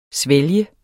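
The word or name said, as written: SVÆLGES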